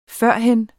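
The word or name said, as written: FØRHEN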